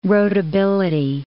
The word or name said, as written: roadability